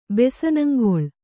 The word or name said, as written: ಬೆಸನಂಗೊಳ್